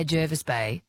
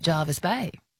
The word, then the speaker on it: Jervis Bay: I think locals say Jervis Bay, Yes. – but many people say, "I'm going to holiday at Jarvis Bay."